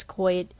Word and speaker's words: quoit: No, actually it is Quoit, according to dictionary.